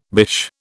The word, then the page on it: bish: British English